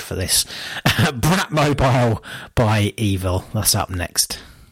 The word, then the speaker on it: Eville: The band is called Evil. That's spelled E-V-I-L-L-E.